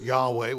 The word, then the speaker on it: Yahweh: The word Lord here is Yahweh, Y-H-W-H, in the Hebrew. Of course, we pronounce it Yahweh, which means we're adding vowels to it, an A and an E there, to come up with, you know, how do you say it?